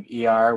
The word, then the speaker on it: Iyar: It's the month of Iyar, which I said is E-R, I-Y-A-R, but really it's spelled in Hebrew with four Hebrew letters.